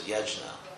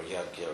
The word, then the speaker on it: yajna: Now, sacrifice, the Sanskrit word is yajna, or yagya, or jaga, different regional pronunciations in India, but written yajna.